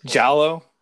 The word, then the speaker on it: giallo: Giallo? Like, is that how you say it? – Like there's always room for giallo. Yes.